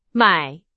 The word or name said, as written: 买